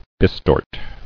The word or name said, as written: Bistort